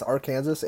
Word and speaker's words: Arkansas: I am so glad I always tell people, I'm like, it's Arkansas and it's Kansas.